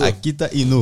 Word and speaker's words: Akita Inu: Sou dono de um Akita Inu. Inu, né? Agora eu falei certo. Isso. Akita Inu.